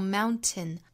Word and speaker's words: mountain: 可以说呢，在美国人之中更多见这种发音方法，像 mountain flattened，基本上我们听到美国人发都会发成 mountain。